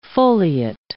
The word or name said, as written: foliate